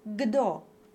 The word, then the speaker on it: kdo: For example, in Czech, they spell the word for who, K-D-O, but they pronounce it gdo. So the D actually voices the K that comes before it.